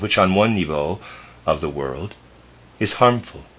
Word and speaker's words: niveau: The word I am pronouncing or trying to pronounce there is N-I-V-E-A-U. I'm pronouncing it niveau.